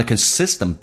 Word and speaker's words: consistent: You focus on，这句话就变成 I'm here to tell you that anything you focus on a consistent basis， on 不被强调，有一个连音叫 on，所以它速度很快，变成 on a consistent basis。 Consistent 指的是持续的，美式发音那个 T 要发成 D，叫 consistent。